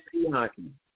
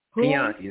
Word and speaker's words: Pianki: Mr. My name is Pianki. Pianki. It's spelled P as in Paul, I-A-N-K-I.